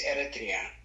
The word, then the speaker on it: Eritrea: No, you say it right. It's Eritrea, so that's what he says. It's correct.